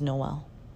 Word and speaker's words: Noel: That can be said Noel, but also Noel. And what is the right way? And I think that's where it's, like, my right way of saying my name is Noel.